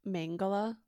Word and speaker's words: Mengele: How do you say the last name? – Mengele.